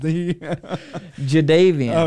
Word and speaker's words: Jadeveon: They traded Jadeveon Clowney, and J.J. Watt got injured. – Wait, they traded who? – Jadeveon Clowney. – Jadeveon? Stop. – Jadeveon.